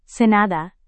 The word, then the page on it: senada: indonesian